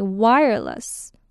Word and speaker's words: wireless: Okay, wireless. Wireless. 虽然它是 L-E-S-S, less 的音，可是念的时候可以是 L-U-S 的音，就变成 wireless.